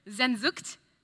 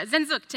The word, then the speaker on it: Sehnsucht: Sehnsucht. Anyone want to correct my pronunciation? Great. Thanks, guys. I really appreciate that. Yeah, Sehnsucht.